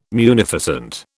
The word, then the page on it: munificent: British English